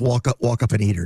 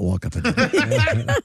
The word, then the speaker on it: Wapakoneta: It's pronounced walk up and eater, not walk up and eater.